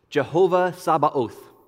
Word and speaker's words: Jehovah Sabaoth: Jehovah Sabaoth is how we're going to pronounce it. Now, this is sort of a transliteration of a Hebrew word that involves a T and a Z at the front of it, so we're not going to go that far with it.